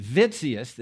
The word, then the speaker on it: Witsius: This book here, The Economy of the Covenants, is written by a guy named Hermann Witsius. This is German, so the W is pronounced like a V.